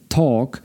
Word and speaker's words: talk: Zwróćcie przy tym uwagę też na to, że w słowie talk nie wymawia się tej literki ly, czyli tam nie, w wymowie nie ma ly, mówi się talk i w przeszłości I talked.